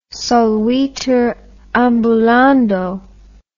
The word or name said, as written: solvitur ambulando